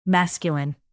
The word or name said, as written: masculine